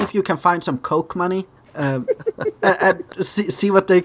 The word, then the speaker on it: Koch: Of mine, and it's pronounced Coke. – Coca-Cola? – I think it's a is that a German pronunciation?